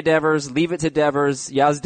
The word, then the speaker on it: Devers: I'm going to give it to leave it to Devers, even though it doesn't rhyme with Beaver.